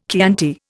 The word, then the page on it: Chianti: Indian